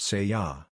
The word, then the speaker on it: SEA: The words that follow are those of a collective energy using the name, S-E-A, pronounced Se-Ya.